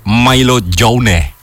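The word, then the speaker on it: maillot jaune: Yellow jersey, or in France we say Milo Jaune. Nih itu penulisannya gini. Wah, Bapak bacanya jauh banget. – Jangan gitu dong. Makasih, Kak. Milo Jaune.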